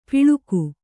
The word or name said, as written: ಪಿಳುಕು